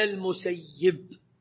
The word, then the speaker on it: المسيب: قال جاء في رواية سعيد بن المسيب عن عائشة رضي الله عنها عند مسلم أما نحن فلا ننطقه إلا المسيب عملا بأهل المدينة، بقول أهل المدينة، وسعيد المدني يعيش بين أهل المدينة وأهل المدينة أعرف به.